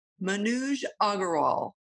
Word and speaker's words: Manuj Aggarwal: So welcome, welcome, welcome to the show, Manuj Aggarwal. – Thank you so much. – Okay. I want you to say your name again because Manuj, with the Southern accent. – Yeah, you got it perfectly.